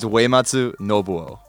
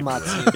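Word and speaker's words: Uematsu Nobuo (植松伸夫): It's Uematsu Nobuo. – Okay, you should read the housekeeper. – Uematsu. – it's like Wei, like W-E for like U-E. It's Wei.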